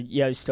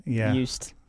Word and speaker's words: Joost: I saw a few people say it rhymes with toast. – Yeah, so Joost or Joost.